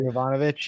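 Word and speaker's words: Yovanovitch: ambassador, Marie Yabranovich, I'm sorry if I'm mispronouncing her name. – Yabranovich, yeah.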